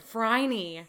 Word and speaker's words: Phryne: Her name is Phryne. – Phryne. Rhymes with Heine.